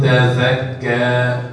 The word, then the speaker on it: تزكى: في حد بيقول تزكى الزين الزاء ازاي دي النطق بدون إخراج اللسان. – فقل هل لك إلى أن تزكى.